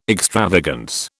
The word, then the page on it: extravagance: British English